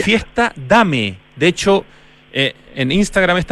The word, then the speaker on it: Dame: No, se llama Dame, bien en español, bien chileno.